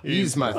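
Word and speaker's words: Yzma: Yuzma and Kronk, however you pronounce Yuzma. – That. Yuzma, thank you.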